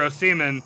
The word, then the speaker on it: Osimhen: Yeah, no, they tried to spend, they sold Victor Osimhen to Napoli.